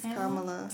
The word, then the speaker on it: Kamala: I said Kamala. – I thought it was Kamala. – It's Kamala.